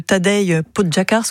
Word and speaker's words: Tadej Pogačar: Ça vous fait donc un point, mais c'est vrai que Tadej Pogačar, c'est comme ça qu'on le prononce, peut l'égaler cette année.